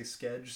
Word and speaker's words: Skedge: It ends with a D, but they say Skedge, so I guess that's what we're doing.